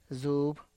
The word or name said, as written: zup